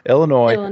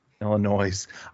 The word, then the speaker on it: Illinois: And I know it sounds bad coming back to Illinois, the S is silent at the end, folks, if you're more of a reader, not a talker. – Illinois. – Illinois.